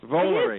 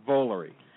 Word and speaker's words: Volary: Are we pronouncing your name correctly? – No, it's actually Volare. – Volare. – It is Volare.